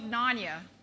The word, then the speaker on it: Narnia: You know, this is from the Chronicles of Narnia.